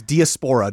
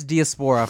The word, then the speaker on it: diaspora: I have trouble finding the correct emphasis on which syllable to put. – It's diaspora, dude. – I thought it was diaspora for the longest time, but then that's a shoe brand, I think.